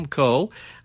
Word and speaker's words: Coe: As a matter of fact, if you have Facebook, you can find me on Facebook, Jim Coe. Last name is spelled C-O-E.